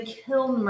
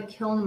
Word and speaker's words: kiln: And you're saying K-I-L-N? Okay. – Does it sound like I'm saying that? Kiln? Am I saying it right? – I'm not hearing the N when you say it, but maybe that's just...